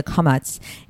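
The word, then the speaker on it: kametz (קָמַץ): In Israeli Hebrew, the kametz and the patach are kind of not so distinguishable in that way, but I'll try to the best of my ability to pronounce the kametz in an Ashkenazi term way, which is o, right?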